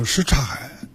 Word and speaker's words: 什刹海: 这个什刹海在北京人读音呢叫什刹海，什刹海就是这个中间这个音呢就读的比较弱。